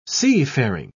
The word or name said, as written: seafaring